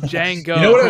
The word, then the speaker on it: Jango: So you butchered two of those names. – What? Which one? – Dooku. – Oh, sorry. – And Jango.